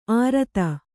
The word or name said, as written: ಆರತ